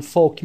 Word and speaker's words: folk: I'm not very keen on folk music. خیلی مشتاق آهنگ های محلی، موسیقی های محلی نیستم. Folk music. الش تلفظ نمیشه.